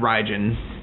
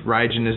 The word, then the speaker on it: Raijin: Raijin is the god of thunder yeah. or lightning. – Lightning. One of those. – I can always remember because Raijin rhymes with Raiden.